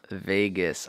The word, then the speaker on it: vagus: Like pronounced like Las Vegas? – Pronounced like Las Vegas, but it's V-A-G-A-S. – Vegas, I see. V-A-G-U-S. – I thought it was V-A-G-A. Anyway, yeah. – Yeah, V-A-G-U-S.